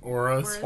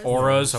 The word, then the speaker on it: auras: aurors? – Auras. – Auras, okay.